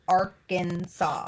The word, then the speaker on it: Arkansas: Arkansas must be pronounced Arkansas.